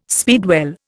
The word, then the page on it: speedwell: Indian